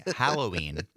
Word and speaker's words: Halloween: Yes, Mike in Spring Green called in during the break for correctly pronouncing Halloween. It is a pet peeve of his when people say Halloween. – Halloween. – It is an A, not an O at the beginning.